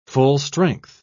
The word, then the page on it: full-strength: British English